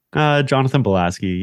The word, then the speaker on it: Jonathan Bielaski: Jonathan Bielaski.